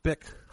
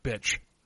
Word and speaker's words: Bich: So the caller, Jennifer Pan, 24-year-old daughter of Huey Han and Bick Ha Pan. And Bick is spelled B-I-C-H, but it's pronounced Bick like the lighter. – Yes. – Yeah, but I watched the documentary where they were calling her bitch.